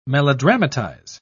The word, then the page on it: melodramatize: British English